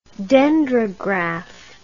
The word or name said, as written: dendrograph